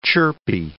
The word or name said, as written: chirpy